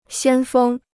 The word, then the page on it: 先锋: xiān fēng